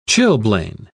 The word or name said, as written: chilblain